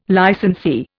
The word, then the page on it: licensee: Indian